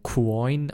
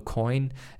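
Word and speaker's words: Quoine: Es geht um einen Gerichtsfall zwischen zwei Firmen, der B2C2, das ist ein Krypto-Liquiditäts-Provider, und Coin oder Coin ausgesprochen, mit Q geschrieben.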